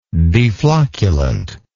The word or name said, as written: deflocculant